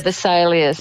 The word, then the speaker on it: Vesalius: The Vesalius, do I say that right? Vesalius? – Yeah, Vesalius.